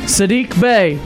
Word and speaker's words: Sadiq Bey: Sadiq Bey? – Sadiq Bey, yes.